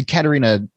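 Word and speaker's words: Catharina: I mean, Stephen, so it's the Caterina sours that you were talking about, and right, am I pronouncing that correctly? – Yes, yeah, that's okay.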